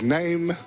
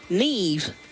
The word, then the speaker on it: Neve: I read it as name, but it is... – Neve. – Apparently, it's... what do you call it? Irish. – Mm-hmm. – Weirdos. – Pretty sure you've got some friends with that name. – Yeah, but it's spelled N-E-V-E, like the Neve preamp.